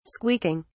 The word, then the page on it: squeaking: British English